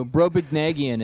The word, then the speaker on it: Brobdingnagian: Brobdingnagian. – Oh, Brobdingnagian. It's from... – Yeah. – It's from... fuck. – B-R-O-B... – Jonathan... Swift. It's the Gulliver's Travels. It's from Gulliver's Travels. – Oh, okay. BROBDINGNAGIAN.